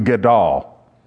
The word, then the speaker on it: gadol: The Hebrew word here is, I'm going to pronounce it, gadol.